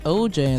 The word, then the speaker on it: 0hjay: You can find me at 0hjay, and that's spelled zero H J A Y.